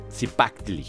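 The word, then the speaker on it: Cipactli: Otro animal muy, muy importante, Zipaxitl, perdón, Zipaxitl Zipactli se pronuncia, perdón.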